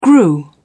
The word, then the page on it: grew: British English